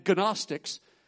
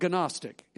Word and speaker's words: Gnostic: That's spelled with a G, a silent G. If you go around and tell people, I'm studying Christology and Gnostics, they're going to know you have an issue.